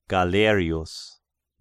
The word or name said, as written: Galerius